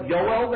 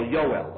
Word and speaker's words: Joel: And therefore, if you look on the bottom of the page, on 278, there's an amazing debate between Abraham Geiger and Manuel, how do you pronounce that name in German? Bella Yoel, they say in German? On the bottom, page 278, J-O-E-L, Yoel, okay?